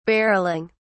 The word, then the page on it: barrelling: British English